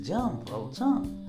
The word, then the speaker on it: Jean Valjean: Jean John. Ah, no, es Jean Valjean.